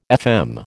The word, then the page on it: FM: American English